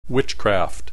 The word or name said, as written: witchcraft